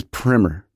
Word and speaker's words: primer: So apparently, the proper pronunciation of primer as it pertains to a, let's say, a book or an explanation, to something that is kind of getting you up to speed on something, is primer.